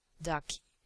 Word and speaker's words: Daki: And it's pronounced in German, Ducky.